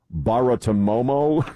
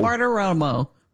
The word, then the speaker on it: Bartiromo: Baratomomo, Romo, Baratomomo. – Bartiromo.